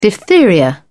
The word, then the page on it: diphtheria: British English